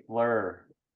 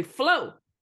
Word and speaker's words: Fleur: When I sing it, I say Fleur. – Fleur. Well, that's how it's spelled in the lyrics. – Yeah. It's because sometimes people say Flo. Name is Flo. – Fleur. – Fleur. – Yeah. – Flower in French. – There you go. – Right. Oh, Fleur, F-L-U-E-R.